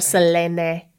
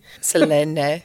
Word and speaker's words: Selene: Selene, if you really want to be pronouncing it correctly. – Selene.